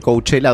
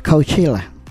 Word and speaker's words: Coachella: Fue la parte oscura, digamos, del Coachella 2025. – Que se dice. Vi varios cómo lo pronunciaban. – Coachella. – No, Coachella. Coachella.